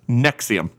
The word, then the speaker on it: NXIVM: the co-founder of the controversial cult-like self-help group Nexium. Again, that's spelled N-X-I-V-M, so it's, you know, trendy. – That's crazy. Okay. – Yeah, so Nexium is,